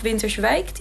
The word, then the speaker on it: Winterswijk: Dus in de buurt had je volgens mij ook nog Winterswijk die tuinrangers hebben, zeg ik dat goed? – Ja, Doetinchem dacht ik Doetinchem.